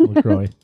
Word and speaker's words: LaCroix: LaCroix.